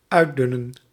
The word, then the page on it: uitdunnen: /ˈœy̯ˌdʏnə(n)/